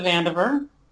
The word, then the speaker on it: Vandever: No, it's actually Lisa Vandever.